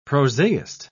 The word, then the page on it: prosaist: British English